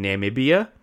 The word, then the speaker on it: Namibia: He wanted to shoot in Namibia, which is a okay.